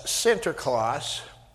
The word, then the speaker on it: Sinterklaas: Now, what happened was Sinterklaas is how it's pronounced in Dutch. But, you know, when the Dutch came to America in the 1600s, settled New Amsterdam, which is now New York, they were mostly Protestant. And Protestants don't like the whole saint thing. So they changed it, and eventually the pronunciation evolved into Santa Claus, and that's how that works.